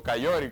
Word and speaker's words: Coyote: sí, porque el Mustang usa el motor Coyote o Cayori, como le dicen ellos por su pronunciación.